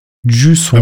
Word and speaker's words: Jusant: Ah, ich habe jetzt gerade noch mal mir das abspielen lassen, wie man äh, Juicent ausspricht, und zwar Juicent. Jusson. Wenn man es wenn man's wirklich äh, französisch aussprechen möchte.